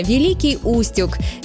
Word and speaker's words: Великий Устюг: Часто можно услышать вариант «Великий Устюг», но это ошибка. Правильно, Великий Устюг. Есть простая подсказка для запоминания. Город расположен у устья реки Юг. Эта географическая связь помогает удержать правильное ударение в памяти.